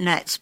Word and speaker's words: gnats: Where they say they pronounce it gnats, but I always like to put the G in so you know what I'm talking about.